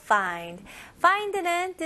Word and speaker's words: find: find. find는 뜻은 우연히 찾다입니다. 네, 발음 연습해 볼게요. 네, F 발음입니다. F 발음은 입술이 붙으면 안 되죠. F, F, 윗니 하고 밑에 입술만 닿는 거예요.